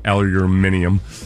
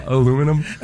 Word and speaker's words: aluminium: I always had, it was like, I wonder if the Brits pronounce his name Drew Aluminium. The way they pronounce aluminum, pronounce it aluminium.